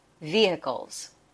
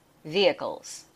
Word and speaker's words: vehicles: This word vehicle can be pronounced two different ways. The first one where you hear the H and the second one where you don't hear the H. So long E, V-hi-cles, vehicles. When you can't hear the H, it sounds like V-i-cles, vehicles. Those last two syllables are the schwa sound, coals, vehicles.